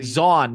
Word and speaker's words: Zaun: Zaun, not Brawn. Zaun. Zaun. Okay. – Let's. – So I definitely wasn't. – Just highlight the four champions in it. So Piltover and Zaun, Teemo, Ezreal, Jinx, and Heimerdinger.